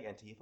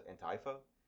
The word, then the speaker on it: Antifa: Apparently, I've been saying Antifa wrong this whole time. Apparently, you're supposed to pronounce it Antifa, because it's not Antifa, it's anti-fascist, so Antifa is the correct pronunciation.